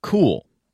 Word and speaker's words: Cuil: This morning, they launched a new search engine called Cool. It's spelled C-U-I-L.